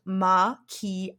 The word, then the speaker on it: macchiato: So a macchiato, pronounced ma-ki-a-to.